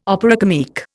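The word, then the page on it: opéra comique: Indian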